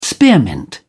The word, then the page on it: spearmint: British English